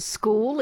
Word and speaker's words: school: It wasn't school, it was skill. – Look, this is especially embarrassing because I've got to admit, I've made a real effort to try and stamp that out of my pronunciation. – No, I mean, it's how you say it. It's fine. We know school. What you mean. – How do Victorians say it? – School. – School. School. – That's better. – Yeah.